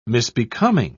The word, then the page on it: misbecoming: British English